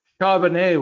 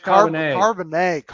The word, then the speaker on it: Charbonnet: Did I say it right? – Hey, you got to get with the French inner Woj. Charbonnet, Woj. Zach Charbonnet. Charbonnet, Woj.